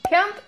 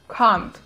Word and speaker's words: can't: В британском произношении слово произносится как can't, с таким твердым длинным звуком а. Can't. А вот в американском произношении ее вообще ни с чем не спутаешь, потому что произносится оно здесь как бы через э, и получается can't.